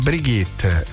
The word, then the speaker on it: Brigitte: Però si legge Brigitte, Brigitte Nielsen,